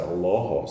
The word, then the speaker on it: Allah: and it's interesting that the way this is pronounced in Arabic, the L is darkened, and it's people say Allah, which has the effect of changing the colour of this vowel as well. It's the only L in Arabic that stands by itself that has that dark pronunciation.